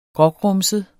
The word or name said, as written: grågrumset